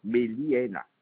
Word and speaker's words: Bélina: s'appelle l'immeuble Bélina. – Alors, immeuble Bélina. – Bélina, comme ça se prononce. Béliéna.